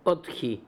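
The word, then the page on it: Otkhi: Russian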